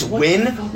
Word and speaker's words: Nguyen: insane, out of control um, uh, um, the, uh, the last name that's spelled N G U Y E N and it's Win?